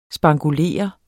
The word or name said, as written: spankulere